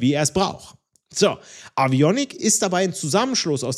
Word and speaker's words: Avionik: Wird so geschrieben, wie ich es ausspreche, nur mit V, also A-V-I-O-N-I-K. Avionik. Das sind die elektronischen Geräte, die dafür sorgen, dass Flugzeuge sicher fliegen.